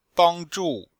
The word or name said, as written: bang1zhu4